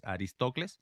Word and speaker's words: Aristocles: Es un poco difícil de pronunciar esos nombres griegos, pero es Aristocles.